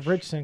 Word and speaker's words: Ritchson: Is it Richardson or Richson? I always fuck it up. – I say Richson. – I think it's Richson because it's spelt like that.